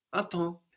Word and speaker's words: à temps: The M on the end of the syllable there gets completely swallowed up. À temps, and the P and the S on the end there also completely disappear. So what looks like attempts is à temps, attends, à temps, attends, and that means on time, on time.